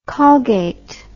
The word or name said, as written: Colgate